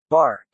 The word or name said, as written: bark